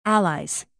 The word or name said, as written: allies